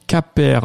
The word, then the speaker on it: CAPER: Allez, on vous parle de CAPER, C-A-P-E-R ou CAPER, si vous voulez le prononcer à l'anglaise,